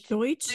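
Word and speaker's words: Deutch: Zoe, how do you say whatever her last Deutsch. – Name is? – Deutsch.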